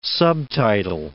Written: subtitle

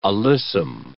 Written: alyssum

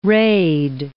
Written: raid

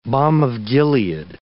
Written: balm of Gilead

